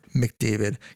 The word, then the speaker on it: McDavid: Connor McDavid, yes, I said it correctly. I did not say McGregor, was released from COVID protocol.